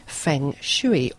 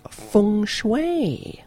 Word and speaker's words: Feng Shui: Now this week we are going to talk about Feng Shui, or as it should be pronounced, Feng Shui.